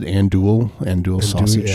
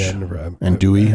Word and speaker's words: Andouille: Andouille sausage. Andouille.